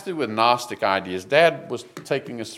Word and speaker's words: Gnostic: Gnosis is G-N-O-S-I-S. Gnosis. And of course, you can see Gnostic. Now, we say Gnostic, we make the G silent, but the Greeks would pronounce it with the G.